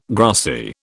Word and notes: grassy: British English

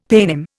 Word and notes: paynim: Indian

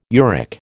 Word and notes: uric: American English

